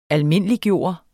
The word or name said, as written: almindeliggøre